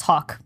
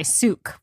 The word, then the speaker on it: Seok: Actually, her Korean pronunciation is Seok, but she goes by Suk, which is how Americans pronounce it.